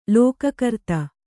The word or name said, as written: ಲೋಕಕರ್ತ